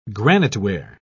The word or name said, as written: graniteware